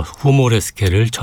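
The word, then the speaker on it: Humoreske: 그렇기 때문에 위모레스크나 휴모레스크 뭐 보다는 독일어 발음도 어렵지 않습니다. 후모레스케 해야 작품의 이해가 쉽습니다. 로베르트 슈만의 후모레스케 작품 번호 20 B플랫 장조 이모젠 쿠퍼의 피아노 연주입니다.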